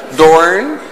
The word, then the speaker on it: Doorn: Dat spreek je ook zo uit. Doorn. Niet Doorn, maar Doorn.